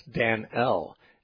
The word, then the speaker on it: Danel: And so there are modern translations that choose to render it as Dan-el, D-A-N-E-L.